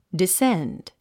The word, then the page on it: descend: disénd; ディセンド